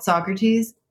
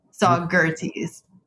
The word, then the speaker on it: Saugerties: We found a town in upstate New York in the Catskill region called Saugerties. Saugerties.